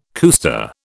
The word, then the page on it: cuesta: British English